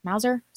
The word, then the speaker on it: Mauser: Lastly, I got a C96 Mauser. Am I saying that right? – Mauser.